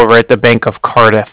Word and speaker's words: Cardiff: And then, yes, the Bank of Cardiff is spelled C-A-R, D as in dog, I, F-F as in Frank Frank. So it's like car and then different. – Okay, so you said C-A-R, B as in boy, I-S-S? – D as in dog.